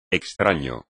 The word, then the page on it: extraño: espagnol